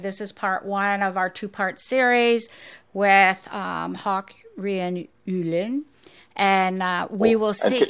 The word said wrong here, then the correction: Håkon Rian Ulin: Can you say it right? – Håkon Rian Ulin.